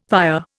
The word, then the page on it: père: Indian